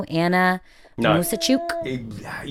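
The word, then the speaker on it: Anna Muzychuk: Anna Musachuk? – Yeah, that's close enough.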